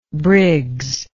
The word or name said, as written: briggs